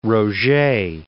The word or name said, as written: Roget